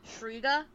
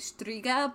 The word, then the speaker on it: Shtriga: They pronounced it in the show as Striga, but there's a T, it's spelled Esh. S-H-T-R-I-G-A. So there's a T in there. – I believe. – So, like, I don't know if the T is silent.